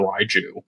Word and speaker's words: Raiju: And at one point, because, you know, Raikou is, you know, he is clearly based off of a mythological creature called, I think it's the Raiju, is how it's, that's how it's pronounced.